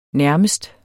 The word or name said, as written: nærmest